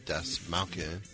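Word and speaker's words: Dastmalchian: It looks like it should be pronounced Dasmalchian. – Okay, Dasmalchian as Kurt, Wood Harris as Gail, and Jordi Mola as Castillo.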